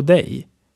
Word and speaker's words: dig: This is written like D-I-G but pronounced G-E-J.